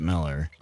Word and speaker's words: Mueller: It's funny you bring Holmgren up, you know, Mueller and Miller, because my wife's maiden name is spelled Mueller, but they pronounce it Miller.